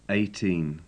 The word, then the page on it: eighteen: English